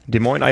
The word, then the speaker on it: Des Moines: Des Moines, sage ich mal. – Des Moines, Iowa.